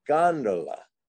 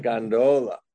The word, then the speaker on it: gondola: And when I was in Venice, I mistakenly said gondola, and the guy got quite angry with me and said, no, it's gondola, not gondola, gondola.